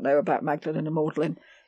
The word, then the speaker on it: Magdalen: In Oxford, Magdalen is pronounced Maudlin. – That's Maudlin? I've always wondered. Because when you look up Oxford, Maudlin doesn't show up anywhere. – No, it's Magdalen is Maudlin. Maudlin. Only in Oxford.